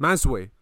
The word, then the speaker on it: Mazuy: Mazuy, I think. – Mazuy. There we go. Mazuy. Look, now you're... – oh, fuck, yeah. How did that happen? Yeah. – Mazuy.